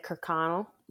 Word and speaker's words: Kirkconnell: Is it Kirkconnell? Connell, yeah.